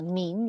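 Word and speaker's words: 明: We pronounce min, repeat, min.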